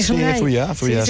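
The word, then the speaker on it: Zuia: Es, ¿Lo he pronunciado bien o lo he pronunciado mal? – Fuya es el municipio donde vivo, bueno, donde viven mis aitas, mis padres, y donde me he criado.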